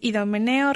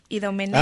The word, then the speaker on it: Idomeneo: Ahí se pronuncia, está, bueno, mal pronunciado, es Idomeneo, rey de Creta, y el rapto del Serrallo.